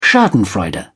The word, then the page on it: schadenfreude: British English